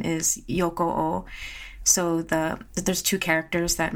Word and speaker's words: Yokoo: And so then you say his last name, Yokoo.